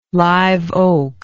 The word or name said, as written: live oak